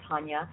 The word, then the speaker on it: Taunia: So you can check out my website at Diva Taunia.